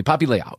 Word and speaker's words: Poppy Liao: So Poppy Liao, who let me say that correctly. Poppy Liao, who is a wonderful actress, and you're going to hear she's coming up, like her interview is coming up.